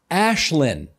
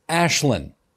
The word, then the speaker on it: Aislinn: Her name is pronounced Ashlyn. It's Gaelic. I know many people didn't know how to pronounce it on election day either. They just needed to know where she was on the very long ballot. For future reference, remember, it's Ashlyn."